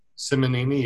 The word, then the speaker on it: Simonini: It's S-I-M-O-N. Is it a Simini? – Simonini, yeah.